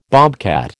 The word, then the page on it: bobcat: American English